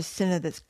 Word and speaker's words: synod: women's ordination conference on its website for the synod, synod, how do you say it? – Synod.